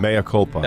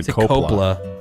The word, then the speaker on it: mea culpa: You said copla. It's mea culpa.